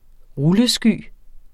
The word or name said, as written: rullesky